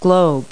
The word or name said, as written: globe